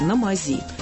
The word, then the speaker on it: на мази: Сразу скажу, в устойчивом выражении «на мази» ударение в слове «мазь» именно такое – «на мази».